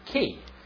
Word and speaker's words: chi: It starts with the letter chi. Some pronounce it key.